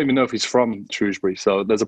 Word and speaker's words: Shrewsbury: The main thing that's just clear up for me is it's pronounced Shrewsbury and not Shrewsbury, as I've been saying it this whole time.